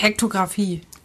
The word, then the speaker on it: Hektographie: Hek Hektographie. – Hektographie? – Ja.